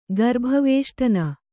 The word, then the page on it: ಗರ್ಭವೇಷ್ಟನ: garbha vēaṣṭana